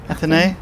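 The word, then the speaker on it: Athénée: The suite in is it called the Plaza Athene? Is that how you pronounce it? – Athene.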